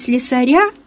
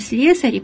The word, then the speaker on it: слесари: Такая тенденция является очень продуктивной, но все-таки целый ряд слов еще сохраняет ударение на «ы», и поэтому все-таки, хотя тенденция говорить «слесаря», потому что доктора, профессора, но мы все-таки с вами говорим «слесари», поскольку «слесаря» – это еще сегодня плохо, это еще не норма.